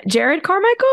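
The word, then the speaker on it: Jerrod Carmichael: Jared Carmichael, is that how you say his name? – Jared yes. Carmichael.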